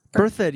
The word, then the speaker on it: Berthoud: Berthoud, yeah.